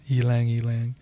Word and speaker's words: Ylang: Now, some of you might be familiar with Ylang and think it's Ylang, but it's pronounced Ylang. Now, of course, if you're Chinese, you might be like, oh, you're not even pronouncing it right.